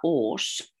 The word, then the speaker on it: Aas: Okay, my name is Salla Oss, and my last name is Norwegian. So everybody in Finland always mispronounces my name because it's a Norwegian name.